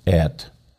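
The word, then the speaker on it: Et (את): It's pronounced Et.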